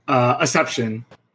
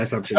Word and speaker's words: Acepcion: Acepcion. – Acepcion? – Yeah, Acepcion.